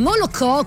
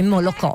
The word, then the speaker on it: Molokò: rilasciata nel 1999, è diventata una vera e propria icona della musica dance, sing back di questo duo elettronico in Molokò, come mi ha insegnato il mio regista Luca Bona, di origine russa. Quindi c'è questo accento con l'O, latte, vuol dire latte, Molokò, Molokò.